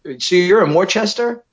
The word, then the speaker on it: Worcester: But I just tell them it's as easy as what our mayor used to say. It's like W-O-O-S-T-A, Worcester.